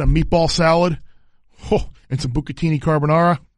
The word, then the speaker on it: bucatini: Bucatini.